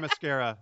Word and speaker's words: Themyscira: How do you say that? – Themyscira. – Themyscira. Thank you.